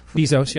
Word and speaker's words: Bezos: Bezos, yeah.